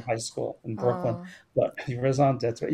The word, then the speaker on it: raison d'être: d'être, raison Raison d'être.